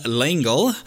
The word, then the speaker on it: L'Engle: Based on the book by Madeline L'Engle.